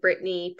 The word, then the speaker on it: Brittney: My handle is It's Me, Brittany P, and Brittany is spelled BRITTNEY.